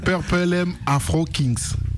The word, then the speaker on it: Purple M Afro Kings: C'est vrai que je le prononce pas bien, Purple Lem. – Purple pour le violet. – Purple. Purple M, c'est pas évident à prononcer. – Oui. – Je me disais à l'antenne la semaine dernière comme quoi c'était pas la bonne prononciation et que vous-même vous allez le prononcer comme il faut. – On te pardonne, Arnold. – Ah bah, t'es au top. – C'est Purple M Afro Kings.